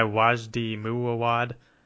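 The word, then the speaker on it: Wajdi Mouawad: And basically the story of what happened is there's a play called Incendies made by this guy Wajdi Muawad. I'm sure I mispronounced that, as I usually do. – Oh, sounds beautifully correct.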